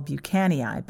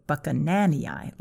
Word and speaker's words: Buchananii: So it's that lovely brown one that I call Buchanii, but it's actually Buchananii, like Buchanan II.